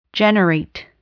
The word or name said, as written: generate